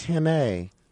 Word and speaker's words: Henne: Is this Heen? How do you pronounce this? – Henne.